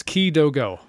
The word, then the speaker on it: Kidogo: Yeah, three syllables. Kidogo.